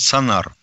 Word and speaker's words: сонар: Американцы изобрели сонар, вот тут не знаю ударение, сонар или сонар. – Ну, обычно да? проносит сонар сонар, да, которые, по мнению же американских экологов, сводят с ума китов и дельфинов.